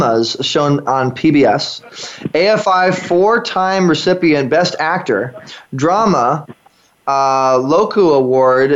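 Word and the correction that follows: Drama-Logue: Did I say that right? Loku Award? – Drama Log Award. – Oh, thank you. Drama Log Award, yeah.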